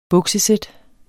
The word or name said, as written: buksesæt